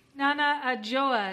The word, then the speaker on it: Nana Adjoa: Nana Adjoa, is that how you say your name? – Yeah, that's me.